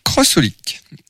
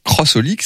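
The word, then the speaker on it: xxxHOLiC: Donc, pour ceux qui ne savent pas que ça se prononce comme ça, parce qu'on n'est pas censé forcément le savoir, sur la jaquette, c'est pas écrit Crossolic, c'est 3X et plus loin, Olic. H-O-L-I-C.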